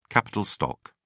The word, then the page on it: capital stock: British English